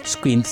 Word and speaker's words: Skint: Skint sería la traducción, eh, la, la pronunciación exacta.